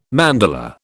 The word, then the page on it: mandala: British English